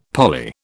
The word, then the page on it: parley: British English